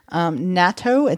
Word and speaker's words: natto: Natto, it's N-A-T-T-O.